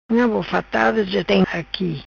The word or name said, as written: Bofatada-Unga